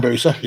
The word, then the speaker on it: Hayabusa: Have I said that right? Hayabusa. – Hayabusa, yeah.